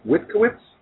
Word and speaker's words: Witkiewitz: This is our second guest, Katie, if I say your name wrong, forgive me, Witkiewitz? – That's correct.